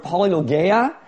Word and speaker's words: polylogia: The other word there is as many words is the word polylogia, and it actually kind of rhymes with diarrhea.